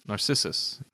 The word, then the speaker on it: Narcissus: N-A-R-C-I-S-S-U-S. – Narcissus.